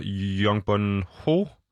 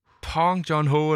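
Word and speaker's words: Bong Joon-ho: Sydkoreansk instruktør Young Bun Ho, Pong John Ho, eller sådan noget. Ja. – Mm, den skal jeg nok jeg lige Jeg tror, det øh, er udtalt som P, faktisk.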